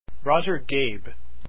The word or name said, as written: Goeb